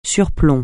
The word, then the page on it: surplomb: French